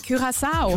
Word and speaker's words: Curaçao: Curaçao.